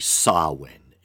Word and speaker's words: Samhain: I know that the proper pronunciation of Samhain, Samhain, is actually Samhain.